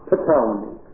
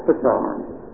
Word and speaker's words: Ptolemy: Now, if you're Greek, I think you pronounce that with a P, Ptolemy. And if you want a mnemonic, the Ptolemies were tolerant. Or maybe I should say Ptolemy.